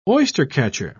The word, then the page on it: oystercatcher: British English